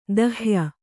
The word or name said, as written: ದಹ್ಯ